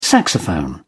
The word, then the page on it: saxophone: British English